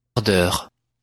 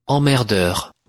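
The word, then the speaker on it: Emmerdeurs: Or perhaps you prefer this pronunciation, Les... – Emmerdeur. Emmerdeur. – Emmerdeur.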